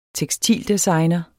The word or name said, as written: tekstildesigner